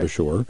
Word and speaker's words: Beshore: Kenton Beshore, I hope I'm pronouncing that correctly, okay. Beshore, B-E-S-H-O-R-E, okay.